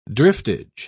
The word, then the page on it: driftage: British English